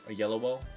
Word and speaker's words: Oyelowo: David Oyelowo, I don't know if I'm pronouncing that correctly. – Oyelowo? – Oyelowo. – That's how I've always heard it pronounced.